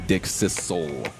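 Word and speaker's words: Dickcissel: Okay, coming in at number two, we have the all one word, Dick Sizzle. – Sizzle? – Sizzle. Now, perhaps the pronunciation does lean a little bit more zzz, but it is a double S, so I'm going Dick Sizzle.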